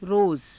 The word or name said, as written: roza